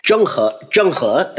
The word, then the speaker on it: Zheng He: Is it Zheng He? Is that right? – Zheng He, that's absolutely right.